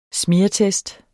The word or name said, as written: smeartest